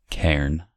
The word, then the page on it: cairn: American English